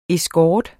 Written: escort

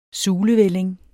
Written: sulevælling

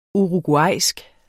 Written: uruguayansk

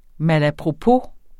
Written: malapropos

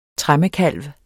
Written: tremmekalv

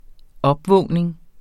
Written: opvågning

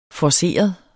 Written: forceret

